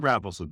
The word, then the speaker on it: Raffleson: That's what you ask. I like both. I like Raffleson better. It's easier. It just goes off the tongue better.